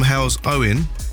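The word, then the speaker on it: Halesowen: Okay, so we should have Joe from Hell's Owen on the line. Joe, you there? – Yeah, hi. – Hello Joe, did I say that correctly? Is that your area? – Yeah, that's fine.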